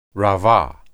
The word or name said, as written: rabah